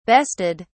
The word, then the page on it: bested: British English